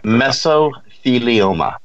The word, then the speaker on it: mesothelioma: Mesothelioma.